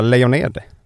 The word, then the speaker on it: Lejoned: Välkommen Laura Lejoned! Uttala ditt efternamn rätt nu. – Det var rätt.